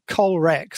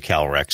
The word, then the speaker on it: Calrec: And I think a lot of people do say Calrec, so you probably won't be too upset at me if I mispronounce it, right? Because probably half the world calls it Calrec.